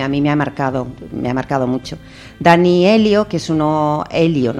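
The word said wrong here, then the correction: Aiello: no sé si lo estoy pronunciando bien, Aello, perdón, que es un actor de cine americano que trabaja sobre todo hacia de mafioso, ¿no?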